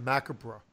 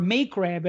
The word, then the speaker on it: macabre: We pronounce that Macabre. – Or Macrab, as my art teacher once said to me.